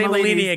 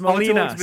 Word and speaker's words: Molina: You can't say Malini again. It's Molina.